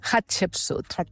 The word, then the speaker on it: Hatshepsut: Hatshepsut. – Hatshepsut. Molt bé, però ho has pronunciat molt bé, eh?